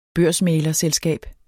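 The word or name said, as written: børsmæglerselskab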